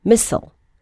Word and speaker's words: missile: You may have noticed the pronunciation for the word missile has a variant, which is more frequent in American English. Do you know it? It is missile.